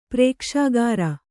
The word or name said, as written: ಪ್ರೇಕ್ಷಾಗಾರ